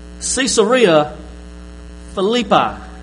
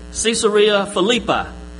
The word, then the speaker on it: Caesarea Philippi: All right, so where and what is the district of, how you say it? Caesarea Philippi. Caesarea Philippi.